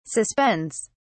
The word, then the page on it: suspends: British English